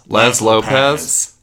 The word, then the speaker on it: Laz Lopez: Chloe Lazpalopaz. Am I saying that correctly? It's Lazpalopaz. – Laz Lopez. Can we say it in sequence? Like, Chloe Laz Lopez.